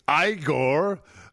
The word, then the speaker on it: Igor: That is Igor. That is how it's pronounced, Froderick, played by Marty Feldman.